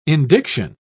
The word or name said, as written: indiction